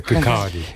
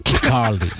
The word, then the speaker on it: Kirkcaldy: Pronuncia Kirkcaldy. – Kirkcaldy.